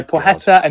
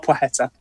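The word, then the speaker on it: Płacheta: It's Szemysław Plecheta or Płocheta. So Płocheta and Szemysław. It's not easy.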